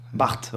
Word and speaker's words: Barthes: Barthes, Roland Barthes.